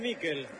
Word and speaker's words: Mikel: ¿Cómo le decimos? ¿Michael o Mikel? Usted que es especialista en nombre. Se escribe Mikel. ¿Cómo le digo? – Decile Mikel.